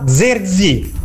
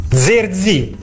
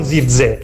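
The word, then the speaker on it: Zirkzee: lui stesso pronunciando il suo nome ha detto: mi chiamo Joshua Zerzi. Zerzi, non Zirze, Zerzi. Quindi impariamo a pronunciarlo bene se dovesse veramente arrivare alla Fiorentina.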